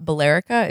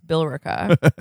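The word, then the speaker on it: Billerica: I've I've learned that it's not Balerica, it's Bilrica.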